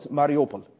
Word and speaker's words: Mariupol: Or Mariupol, as they say. It's like awful in the news media. Mariupol. It's Mariupol is the way you pronounce that.